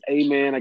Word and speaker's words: Amen: I'm a founding principal, Principal Ra, Amen Ra. First name is Amen. I get that a lot. It is spelled just like after prayer, A-M-E-N. And I'm a founding principal,